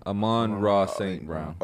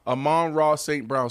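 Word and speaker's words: Amon-Ra St. Brown: Amon St. Brown. Did I say that right? – Yeah. Amon Ra St. Brown. – Amon Ra St. Brown.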